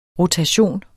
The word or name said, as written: rotation